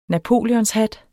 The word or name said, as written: napoleonshat